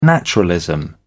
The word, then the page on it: naturalism: British English